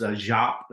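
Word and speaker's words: Giáp: I think it's pronounced Giap, it's like G-I-A-P or I-A-P-E or something like that, asked him if the attack occurred, and he said no.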